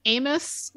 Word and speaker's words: Amos: But regarding Amos Yee, and I believe it's pronounced Amos. When I was a kid, there were famous Amos cookies. So Amos rhymes with famous.